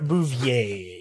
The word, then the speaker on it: Bouvier: Pierre Bouvier. – Pierre Bouvier, yes.